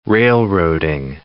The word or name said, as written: railroading